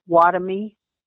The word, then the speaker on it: Wahtomy: Okay, his name is Ambrose Watomy, W-A-H-T-O-M-Y.